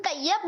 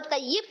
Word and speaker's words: طیب: لیکن طیب غلط تلفظ ہے اور طیب صحیح تلفظ ہے. جیسے کہ پہلا کلمہ طیب یہ غلط تلفظ ہے, پہلا کلمہ طیب یہ بالکل صحیح تلفظ ہے.